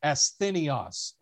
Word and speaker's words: asthenios: So the Greek word for weakness there is the Greek word asthenios, and I'll spell it for you. It's A-S-T-H-E-N-I-O-S. A-S-T-H-E-N-I-O-S.